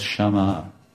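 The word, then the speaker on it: Shyama: Radharani is known by more common names, but Radharani is also called Shama, like Shama and Sham. So this, when you add long A at the end of the word, it becomes feminine, like Ram and Rama.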